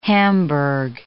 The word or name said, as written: hamburg